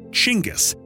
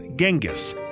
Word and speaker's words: Chinggis: One thing people here can agree on is that it's pronounced Chinggis, not Genghis.